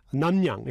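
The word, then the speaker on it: Namnyang (납량): Receive a chill or receive cool. Namryang. Namnyang, excuse me.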